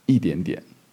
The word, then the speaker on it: 一点点: 一点点, sorry. I actually pronounced it wrong there. So 一点点. There's two tones, yeah, as far as I'm aware, there's two tone changes in one word there. So yeah, it's quite a lot going on. So look at these characters separately. Yi is pronounced first tone. Only ready when it's a number. But when after the E comes a second, third, or fourth tone, I believe, yeah, it becomes a fourth tone.